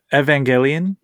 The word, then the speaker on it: Evangelion: So JP picked up a Evangelion.